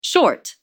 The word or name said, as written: short